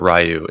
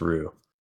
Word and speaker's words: Ryu: 14-year-old Davey just showed up in my head and said, "It's not Ryu. It's just pronounced Ru."